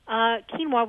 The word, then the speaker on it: quinoa: Quinoa takes about 15. Quinoa, which is spelled Q-U-I-N-O-A, a lot of people call it quinoa, but it's called quinoa, is available in most natural food stores.